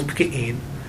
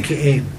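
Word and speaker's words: Peki'in: a small village called Pki'in.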